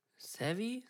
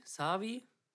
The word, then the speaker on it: Savvy: Hier, wie, wie spricht man den aus? – Savvy? Savi? S-A-V-V-Y. – Ja, S-A-V-V-Y.